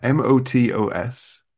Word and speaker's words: Motos: Yeah, Motos.